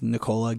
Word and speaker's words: Nicola: I have the stupidest name because my name is Nicola Gilbert Gibson. – And Nicola is not spelled like the real Nicola. – N-I-C-O-L-A.